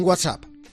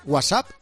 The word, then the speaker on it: WhatsApp: ¿Tú eres de los que dice, WhatsApp? ¿O de esos otros que marcan la T WhatsApp, como dando a entender que ellos sí saben pronunciarlo y los demás no? – No, yo digo WhatsApp.